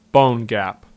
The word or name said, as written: Bone Gap